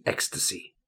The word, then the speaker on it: ecstasy: Be careful with the pronunciation. Ecstasy.